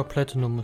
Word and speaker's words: Platinum: Ja, Platinum ist schon in Ordnung